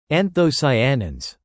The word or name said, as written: anthocyanins